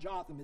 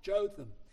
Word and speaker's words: Jotham: So in the second year of Pekah, the son of Remaliah, king of Israel, Jotham, and I did look that up, and it is a long O. It's not Jotham, it's Jotham, according to the Westminster Dictionary of the Bible.